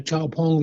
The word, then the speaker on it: XPeng: Man ist mit gemeinsam einem chinesischen Startup, XPeng oder XPeng, wie es die Chinesen aussprechen.